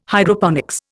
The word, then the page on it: Hydroponics: Indian